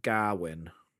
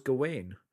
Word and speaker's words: Gawain: This is the first time I've ever heard it pronounced Gawain. – Gawain, interesting. – No, but that's how it's said in the movie, isn't it? – Yeah. – It's Gawain. – Yeah. – Whereas I read it and I always thought it was Gawain.